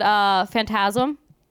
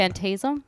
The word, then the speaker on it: Phantasm: You're saying Phantasm. You're putting the wrong emphasis on the wrong syllable.